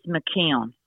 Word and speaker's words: McKeon: Just because people might see it's E O, it's uh, it's actually M C K E O N.